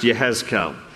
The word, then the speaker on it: Yehezkel: His name in Hebrew is pronounced Yehezkel.